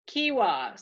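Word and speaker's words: kehwa: Oh, the book says it's pronounced Kiwa. So pronunciation, E is in key, A is in father, accent key, and pronounced Kiwa. – So like kiwi, but Kiwa.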